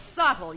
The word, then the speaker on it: subtle: No, it's not, it's subtle. You don't pronounce the B.